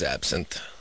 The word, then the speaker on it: absinthe: Yeah, it's absinthe.